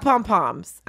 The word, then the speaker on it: pom-pom: You can be correct, but it could be spelled H-E-V-Q-T-R, but it's still pronounced pom-pom.